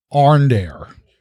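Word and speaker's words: Aurandair: And you are delivering it to Arndair. – Oh, okay. – Yes, the queen's ambassador to Thrain. And you are delivering it to Arndair.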